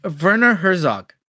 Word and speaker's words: Werner Herzog: So, you know, we're going to spoil pretty much the whole speech, but how do you say it? Werner Herzog, is it? – Werner Herzog.